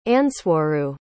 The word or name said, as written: andswaru